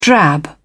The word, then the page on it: drab: British English